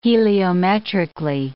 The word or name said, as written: heliometrically